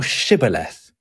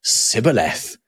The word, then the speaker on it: shibboleth: if he say nay, then said they unto him, say now shibboleth. And he said, sibboleth, for he could not frame to pronounce it right.